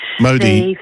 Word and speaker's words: Modi: But can I also say, I also want to highlight to mention that Modi from India, I don't know my pronunciation. – Modi.